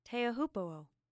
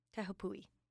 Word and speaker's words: Teahupo'o: It's pronounced Teahupo'o. Pui. Teahupo'o.